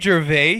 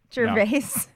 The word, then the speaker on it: Gervais: Is that Ricky Gervais here? – Gervais? – No, I pronounce it the French way.